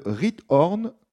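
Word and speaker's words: Giethoorn: Quelle est la particularité de la ville de Riethoorn, c'est à peu près comme ça qu'on prononce, aux Pays-Bas ?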